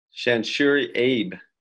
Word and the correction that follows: Sanshiro Abe: unless I'm saying that wrong. Penn State. – That's Sanshiro Abe.